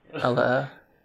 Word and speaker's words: Ella Eyre: I can't pronounce the name, Ella Ella Ella Eyre.